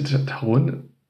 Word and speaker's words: Taron: Äh, wie bitte Taron? Äh, meinen Sie Taron? Ach ja, stimmt, so ja, wird das ja ausgesprochen.